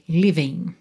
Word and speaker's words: living: Lembra que aqui a gente teria uma letra G, living, ok? Terminação ING. O G é mudo.